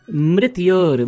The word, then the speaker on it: mrityor: Mitror mushki. – Mitror mushki. No, it is mrityor mukshya. Mrityor. Yes. Mrityor.